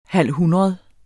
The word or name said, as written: halvhundrede